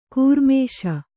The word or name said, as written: ಘೂರ್ಮಿಸು